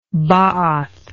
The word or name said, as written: baath